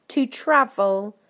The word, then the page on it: to travel: British English